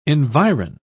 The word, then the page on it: environ: British English